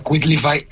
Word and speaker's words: Quitlify: Se pronuncia Quitlify. Quitlify. – Quitlify. – Quitlify, como de quit. – Sí, en inglés.